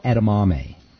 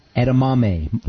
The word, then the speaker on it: edamame: edamame. Edamame is how you say it. – Edamame. – Like mommy. Mame. Edamame.